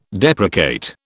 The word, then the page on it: deprecate: British English